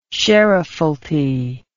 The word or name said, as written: sheriffalty